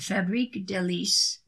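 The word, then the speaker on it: Fabrique Délices: Isigny Saint Marie and Fabrique Delice black truffle butter. Isigny. Saint, S-T-E, period, hyphen, mer, M-E-R-E, X, F-A-B-R-I-Q-U-E, delicious, D-E-L-I-C-E-S, black truffle butter.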